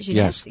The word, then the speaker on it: Jeunesse: I'm saying it right now, right? – Jeunesse. Yes.